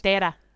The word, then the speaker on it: Formentera: It's on a little tiny island off of like the big part of Spain called, I'm going to say it's called Formentera, but who knows how it's actually pronounced. – Formentera.